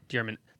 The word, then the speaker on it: Dearman: How do you say his last name? – Brent Dearman. – Dearman. There's people from my hometown with the same spelling of that name. It's Dearman.